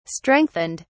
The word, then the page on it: strengthened: British English